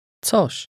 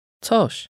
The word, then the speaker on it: coś: Coś. Coś. Coś. – Notice the ts sound at the beginning. Say this word again. – Coś.